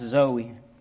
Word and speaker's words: zoe: This word life in the Greek, it's a word pronounced Zoe, Z-O-E.